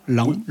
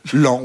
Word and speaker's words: Laon: J'ai toujours prononcé... Lan. – Oui. Lan, oui, c'est Lan. Ça. On dit Lan, je crois, en général. Oui, Lan. Ça s'écrit L-A-O-N. Mais on dit bien Lan.